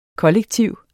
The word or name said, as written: kollektiv